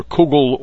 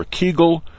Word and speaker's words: kugel: Wikipedia dictionary says that kugel is from the Yiddish, which can be pronounced either kugel or kegel, depending on where you come from.